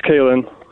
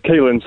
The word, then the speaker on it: Callan: Yes, it's Callan. – So you were incorrect. – Well, it's Callan, so it's like Colin, but it's got an A instead of the O.